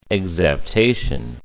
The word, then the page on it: exaptation: ex.ap.ta.tion